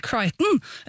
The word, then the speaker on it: Crichton: Og dette her jo en serie skapt av Michael Crichton. Eller Crichton, tror jeg. – Ja, takk, jeg kan ikke uttale noen ting i dag. Men uansett, Michael Crichton mannen bak så ulike filmer som Westworld, Jurassic Park og Twister.